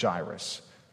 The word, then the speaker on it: Jairus: Jairus is how I read his name for a long time. Every now and then, I still pronounce it Jairus. I think it's Jairus. So if I confuse you with that, my apologies.